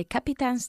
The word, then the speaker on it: captain's: At the beginning of the week we sat at the captain's table. – At the captain's, not capitán, the captain. – Captain's. – Not captain, captain's. – Captain's. – Tin, tin, captain. – Capitins. – Not capi, captain, dos sílabas.